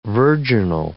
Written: virginal

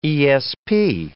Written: ESP

